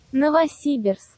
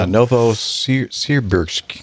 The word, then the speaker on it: Novosibirsk: Novosibirsk. – I still can't understand what she's saying. – She's about 13 years old. – I think so. Okay. Yeah, Novosibirsk. – Novosibirsk.